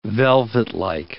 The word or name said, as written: velvetlike